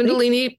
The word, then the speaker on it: Kundalini: So you had this feeling to write, then you found this Kundalini. Am I saying it right? – Yep, exactly. – Kundalini meditation.